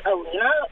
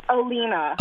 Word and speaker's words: Aleighna: So my younger sister, it's Alina, but she spells it really weird. It's spelled A-L-E-I-G-H-N-A. – What? – Wait, what's the name? – Alina. – Alina? – And how do you spell it? – A-L-E-I-G-H-N-A, like the female version of V.